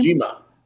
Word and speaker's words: Kojima: Um, so in the men's bracket, we're gonna get CM Punk versus uh, Satoshi uh, Kojima. I believe is how you pronounce his name. Kojima.